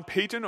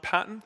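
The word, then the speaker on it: Paton: And the story comes from a missionary, a man by the name of John Payton, or sometimes pronounced John Patton.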